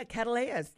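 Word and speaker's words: Cattleya: I have been interested in orchids, and right now I have a, I think if I pronounce it right, a Cattleyas. – Yeah, Cattleyas.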